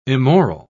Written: immoral

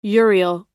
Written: urial

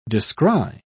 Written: descry

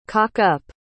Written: cock-up

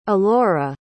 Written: alaura

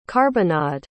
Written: carbonnade

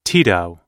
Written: Tito

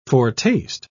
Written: foretaste

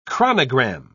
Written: chronogram

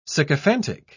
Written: sycophantic

